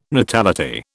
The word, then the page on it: natality: British English